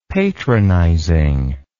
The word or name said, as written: patronizing